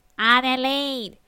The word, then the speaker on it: Adelaide: I said, shush, it's pronounced Adelaide.